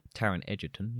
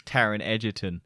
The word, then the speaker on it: Taron Egerton: It's kind of a similar story with like Taron Egerton. Taron Egerton, yeah.